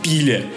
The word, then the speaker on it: пиле: Хотя на самом деле она «изгрев», курица по-болгарски будет пиле, хотя правильно говорить пиле.